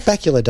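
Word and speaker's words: Bakula: Scott Bacula does rhyme with Dracula, admittedly.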